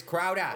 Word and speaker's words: Crowder: Say it right, it's Crowder.